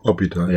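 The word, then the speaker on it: Hôpital: Hospital. Ja. Hospital. In Französisch spricht man keine H.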